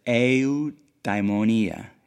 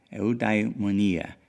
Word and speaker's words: eudaimonia: but it's eudaimonia, eudaimonia, E-U-D-A-I-M-O-N-I-A, and it's traditionally translated as happiness.